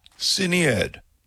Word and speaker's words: Sinead: It is, but as a kid I got Sinead, Sinide, and I had terrible pronunciation of my name. – Sinead.